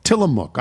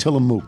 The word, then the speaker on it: Tillamook: The mook part is pronounced like cook. Tillamook. I said Tillamook.